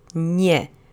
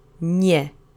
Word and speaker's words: nie: It's one syllable. Let's say it together three times. Nie. Nie. Nie.